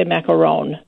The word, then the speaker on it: macaron: And both are pronounced macaroons. – Yes. Okay. Well, if you ask someone that's French, they would probably say macaron. – Oh, macaron. Yes, that's it.